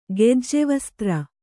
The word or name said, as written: ಗೆಜ್ಜೆವಸ್ತ್ರ